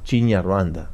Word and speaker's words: Kinyarwanda: Kinyarwanda. – Sì, pronunciate bene questa è la nostra lingua.